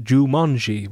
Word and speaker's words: Joomanji: By the band Jumanji, which is J-O-O-M-A-N-J-I.